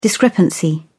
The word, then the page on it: discrepancy: British English